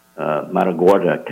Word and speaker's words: Matagorda: And let's talk a little bit about the Matagorda County.